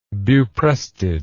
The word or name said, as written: buprestid